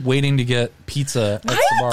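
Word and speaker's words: Sbarro's: Kyle, how do you say it? Sbarros? – Sbarros. Oh, yeah.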